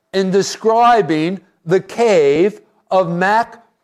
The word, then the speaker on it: Machpelah: So something else here, and this brings us to our fourth point, and that is the cave of Machpelah. That's the actual way of pronouncing it. I've already killed it like three times. It's Machpelah.